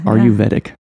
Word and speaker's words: Ayurvedic: Ayurvedic medicine, did I say that right? – Yeah. – Ayurvedic.